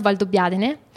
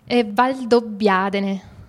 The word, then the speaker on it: Valdobbiadene: The region, yes, in fact, I don't like to say Prosecco, but because the most important area for Prosecco is Conegliano Valdobbiadene, which is now the DOCG. – That's the Conegliano, how do you say the last word, Volley? – Valdobbiadene.